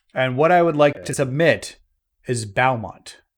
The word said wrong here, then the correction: Bahamut: Am I saying that right? Balmont? – Bahamut? So, Bahamut. There we go.